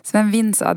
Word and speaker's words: Sven Vinsa: Uttalar jag rätt? – Ja, ja, du säger rätt. – Sven Vinsa, det var min första tränare.